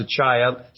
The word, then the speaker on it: Achaia: I think I pronounced it wrong last week. It should be pronounced Achaia, southern Greece.